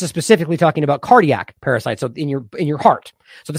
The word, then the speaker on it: pericytes: Now, weirdly enough, it's pronounced parasites for the podcast. I looked it up even. It's spelled P-E-R-I-C-Y-T-E-S.